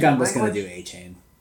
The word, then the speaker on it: Achane: Well, now it's Devon Achane. We're supposed to say Achane instead of Achane.